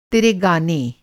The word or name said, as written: तेरा